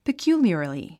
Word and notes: peculiarly: pikjúːljə r li; ピキュゥリアラリィ

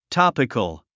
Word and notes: topical: tάpik ə l; トォピコゥ

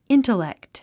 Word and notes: intellect: íntəlèkt; イントレェクト

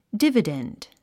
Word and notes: dividend: dívədènd; ディバデンド

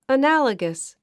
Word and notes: analogous: ənǽləɡəs; アナラガス

